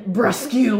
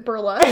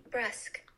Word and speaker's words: brusque: Also, Haley earlier pronounced it burlesque, and I don't know where she got that from the word. – I would like to hear the pronunciation. – Brusque.